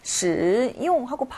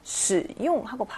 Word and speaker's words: 使用: 근데 使 하고 내려가는 걸 정확하게 외워줘야, 아, 이게 삼성이구나 라는 걸알 수가 있어요. 왜냐면 使用하고 발음되지 않고 使用하고 발음되기 때문에 그렇죠.